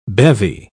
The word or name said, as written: bevy